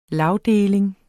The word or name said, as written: lagdeling